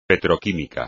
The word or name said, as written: petroquímica